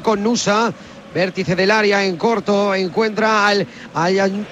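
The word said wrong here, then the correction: El Aynaoui: El Ainaui, el ex de la Roma.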